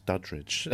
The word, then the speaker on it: Duddridge: Paul Duddridge. Um, I don't know why I added a sort of syllable into his name there. It's Duddridge, um,